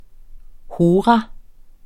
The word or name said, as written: hora